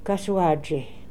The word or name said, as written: Kasoaadjy